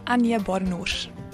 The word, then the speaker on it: Anje Badenhorst: Anje Badenhorst.